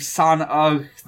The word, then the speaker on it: Son-Ugh: I just wanted to bring it up because it has a character named Son-Ugh the Bog Hog. – Yes, it's spelled, fucking S-O-N... – U-G-H. Like, ugh, like a caveman would say.